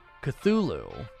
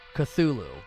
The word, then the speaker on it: Cthulhu: Is it Chatulu? Tulu? I don't know. I don't know how to pronounce that. I always see that and I'm like, I'm not from that world. I don't know how to pronounce that. Everybody always has the shirts and their avatars, and I never know how to pronounce it. It's Cthulhu. Cthulhu.